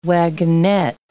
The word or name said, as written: wagonette